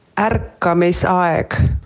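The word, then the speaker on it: Ärkamisaeg: Dit spreek je uit als Arkamisajek. Ik spreek het ongetwijfeld niet goed uit, dus ik heb even een Estse gevraagd hoe je dit uitspreekt. Daar komt-ie. – Arkamisajek.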